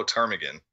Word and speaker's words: ptarmigan: The state bird of Alaska is the willow ptarmigan, and that's ptarmigan spelled with a P.